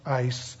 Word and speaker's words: eis: it's a little conjunction spelled E-I-S in the Koine Greek. It's pronounced ice, spelled E-I-S.